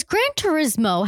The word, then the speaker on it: Gran Turismo: So wait, is Gran Turismo how you say it? – Yeah. – I always thought it was like Gran Torino.